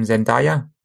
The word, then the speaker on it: Zendaya: Forgive me if I mispronounced that actress's name, Zendaya.